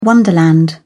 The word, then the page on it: wonderland: British English